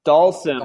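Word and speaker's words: Dhalsim: So in your head, spell it D O L L S I M. Dalsam. – Dalsam. Dalsam.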